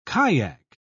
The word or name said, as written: kayak